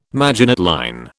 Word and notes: Maginot line: British English

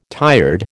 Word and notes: tired: American English

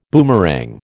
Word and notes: boomerang: American English

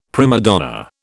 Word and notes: prima donna: British English

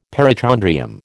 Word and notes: perichondrium: American English

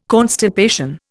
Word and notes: constipation: Indian

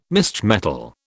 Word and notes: misch metal: British English

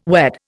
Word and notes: wed: Indian

